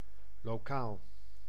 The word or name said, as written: local